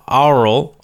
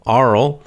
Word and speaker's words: aural: But it should be our, aural, aural, binaural, binaural, a u R A L, aural, means related to hearing, related to your to the ears or hearing, connected to that topic of ears and hearing.